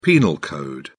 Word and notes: penal code: British English

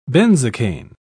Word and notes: benzocaine: British English

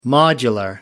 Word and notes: modular: British English

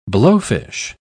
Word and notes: blowfish: British English

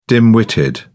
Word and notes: dim-witted: British English

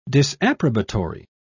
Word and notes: disapprobatory: British English

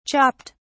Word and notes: chopped: British English

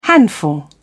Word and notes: handful: British English

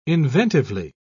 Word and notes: inventively: British English

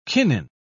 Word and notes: kinin: British English

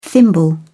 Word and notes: thimble: British English

